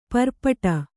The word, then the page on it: ಪರ್ಪಟ: parpaṭa